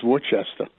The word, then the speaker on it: Worcester: I actually worked up in Worcester with Verizon when I got a job out of college with Verizon.